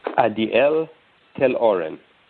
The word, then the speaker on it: Adiel Tel-Oren: Ariel Tel-Oren.